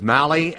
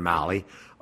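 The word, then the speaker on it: Mahle: He'll be in with all the sports headlines, highlights, talk about a Twins win last night, kind of a platoon win with Mally and I think I'm saying that right, M-A-H-L-E. I think I'm hearing Mally, the pitcher that the Twins were able to acquire He put together that win.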